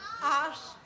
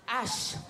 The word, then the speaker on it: Arsch: Arsch. – Arsch.